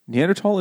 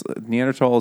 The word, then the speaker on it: Neanderthal: Neanderthal is the OG pronunciation. – No, but it sounds so stupid. – It's because it's a German word.